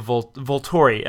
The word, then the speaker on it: Volturi: Now we bring in the Volturi.